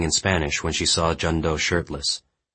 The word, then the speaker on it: Jun Do: "It's Pak Jun Do," Jun Do said. Then he pronounced it slowly. "Jun Do."